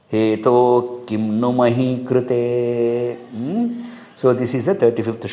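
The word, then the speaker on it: hetoḥ: That visarga you should not pronounce. What I mean to say is that hetoho kimnu mahi krute need not be pronounced.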